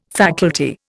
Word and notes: faculty: Indian

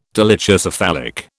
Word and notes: dolichocephalic: British English

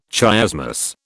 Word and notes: chiasmus: British English

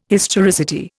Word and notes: historicity: Indian

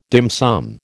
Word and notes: dim sum: American English

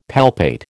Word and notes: palpate: American English

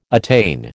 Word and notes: attain: American English

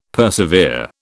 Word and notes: persevere: British English

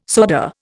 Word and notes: soda: Indian

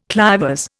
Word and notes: clivers: Indian

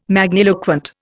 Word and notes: magniloquent: Indian

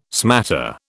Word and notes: smatter: British English

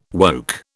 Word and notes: woke: British English

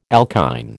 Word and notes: alkyne: American English

